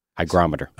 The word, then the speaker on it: hygrometer: And you should have a hydrometer. – Hygrometer. – Hygrometer, yes. Sorry. Did I say that wrong? – You did. – I did. So I was wrong and you were correct this time.